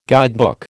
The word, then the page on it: guidebook: American English